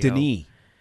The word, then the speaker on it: Denis: Denis. I found out how his first name is pronounced. It's Denis.